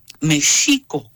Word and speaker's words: México: En náhuatl era México con uh-huh. acento en la I. – Uh-huh. – Y la pronunciación era como shampoo. Ajá. O shower en inglés.